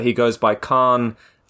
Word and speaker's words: Khavn: How do you spell that? – K-H-A-V-N. – Oh, and it's pronounced Khan.